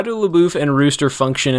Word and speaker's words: LaBeouf: LaBeouf. He pronounces it LaBeouf, yeah.